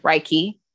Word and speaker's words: Reiki: Reiki. – When I think of Reiki healing, I think of like someone like is a room.